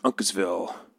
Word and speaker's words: Uncasville: is it Uncansville, Connecticut, at the Mohegan Sun Arena? – Yeah, it's pronounced Uncasville.